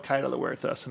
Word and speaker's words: Huertas: I mean, the name of the restaurant, and I'll just say it myself, Huertas. I never realized it was such a hard thing to pronounce. – This damn H.